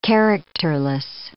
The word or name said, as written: characterless